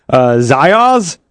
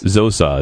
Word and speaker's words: Zosoz: Zios? – Zosaz. – I'm going to say Zios. – There's no I in it. Z-O-S-O-Z. – Zoaz? Zosaz? – Zosaz.